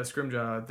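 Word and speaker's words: Scrimgeour: Scrimjaw? Is that how it's really pronounced? – Yeah, Scrimjaw.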